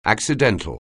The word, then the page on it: accidental: anglais